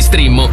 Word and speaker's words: Streammo: Entra adesso nel mondo di Strimmo. Vai su strimmo.it. Si scrive Streammo o scarica l'app.